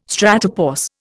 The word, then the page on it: stratopause: Indian